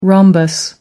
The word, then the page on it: rhombus: American English